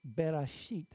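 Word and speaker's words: Bereshit (בראשית): It's Bereshit. That's how it's pronounced, and you've got Bar.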